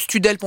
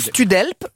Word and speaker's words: Studhelp: Et donc ça c'est sur le site de Studelp, on va redire comment ça s'écrit pour qu'on comprenne bien. S-T-U-D-H-E-L-P. Voilà, H-E-L-P, donc Studelp.